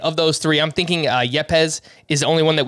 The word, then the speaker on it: Yepez: I should have looked up how to say his name. Yepez. – That's correct. – Yeah, Yepez.